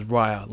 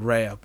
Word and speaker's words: Raya: Now, it was surprising to hear her name pronounced as Raya, like Maya, the 3D animation software. I always just kept calling it Raya,